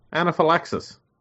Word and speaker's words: anaphylaxis: Yeah, anaphylaxis. – Wow, that's crazy. How's that? Even pronounced it properly first time round. Yeah, he did.